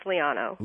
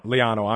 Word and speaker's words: Liano: First hour, I'm going to have my guest now, Blair Liano Helvey. I did pronounce that correct, right, Blair? – You were close. It's Liano. – Liano, I'm sorry.